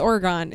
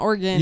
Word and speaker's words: Oregon: It's Oregon. It's not Oregon.